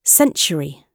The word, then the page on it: century: British English